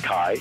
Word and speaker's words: chi: And the Greeks spell it, and phonetically, the, the letter that makes the k sound, the Greeks write as a big X. It's called chi. And the R sounding letter is called rho, and it's written as a big P.